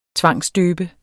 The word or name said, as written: tvangsdøbe